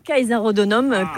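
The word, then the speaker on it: Caesarodunum: Plutôt Kaiser Hodonum, Kaiser Hodonum, même si on est vraiment latiniste. Hein, c'est les prononciations, voilà, qu'on opère normalement.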